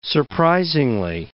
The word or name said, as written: surprisingly